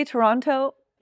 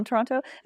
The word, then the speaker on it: Toronto: I can't remember who I was talking to a friend as well who's from Toronto, and they were like saying that's how you know you're not from there, is if you pronounce the T.